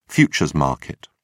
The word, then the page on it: futures market: British English